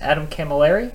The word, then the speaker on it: Camilleri: This is Adam Camilleri.